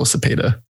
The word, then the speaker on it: Cepeda: So it's just Oswaldo Cepeda.